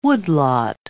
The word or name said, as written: woodlot